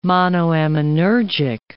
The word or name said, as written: monoaminergic